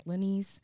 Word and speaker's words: Lanise: I know people forget to pronounce my name like Lanise, but I always tell them that it's like Denise, but with an L.